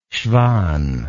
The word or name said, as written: schwann